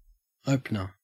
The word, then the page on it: opener: Australian English